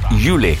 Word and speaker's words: Yeule: Lei si chiama Yule. Yule è un'artista di Singapore, si scrive Y-E-U-L-E, nata a Singapore ma ora residente a Londra.